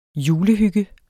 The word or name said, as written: julehygge